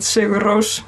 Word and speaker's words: Sigur Rós: So our two most or best known musical artists in the world would be Björk and Sigur Rós, since we're pronouncing it the Icelandic way.